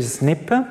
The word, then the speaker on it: SNP: qu'on appelle aussi SNP, prononcé comme ça, que ça vient de l'anglais, Single Nucleotide Polymorphism, c'est simplement le changement, dans l'occurrence ici, d'une C à un T.